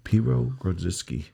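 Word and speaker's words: Piwo Grodziskie: Piwo Grodziski.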